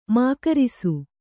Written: ಮೂಗೇರಿಸು

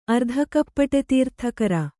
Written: ಅರ್ಧಕಪ್ಪಟೆತೀರ್ಥಕರ

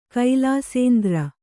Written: ಕೈಲಾಸೇಂದ್ರ

